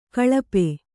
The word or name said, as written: ಕಳಾಪಿ